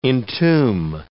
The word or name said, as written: entomb